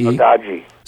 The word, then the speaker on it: Saudade: but it's – how do you pronounce that? S-A-U-D-A-D-E? – Saudade. – Saudade, okay.